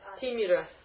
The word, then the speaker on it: Timira: Is it a long Timira? – It's a short. – Timira. – Would you write it on the board? – Yeah. Short E. Even though it's such a funny thing because even though I check this over and over, my mind just supplies the right letters, you know. Tejas Timira Sankavat. So you've got to make a short E there.